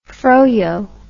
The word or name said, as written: froyo